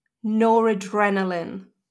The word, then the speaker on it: noradrenaline: which was noradrenaline. So it's adrenaline, but with no at the start.